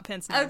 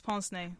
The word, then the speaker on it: pince-nez: It is not pince-nez. – Okay, I was not sure how to pronounce that correctly. I would just went with it. – It's French. It's pince-nez.